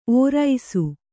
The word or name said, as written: ಔರಸ